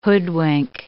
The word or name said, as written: hoodwink